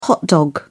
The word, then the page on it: hot dog: British English